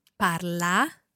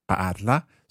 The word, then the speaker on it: parla: Parla. – Parla. So, the a sound at the end.